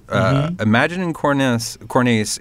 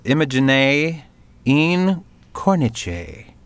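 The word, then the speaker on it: immagine in cornice: I'm going to read it the dumb American way first. Imagining Cornice is Italian for picture in a frame, which I knew that. I did not look up the pronunciation, though. And it's Imagine in Cornice, which I'm still, I fucking butchered it.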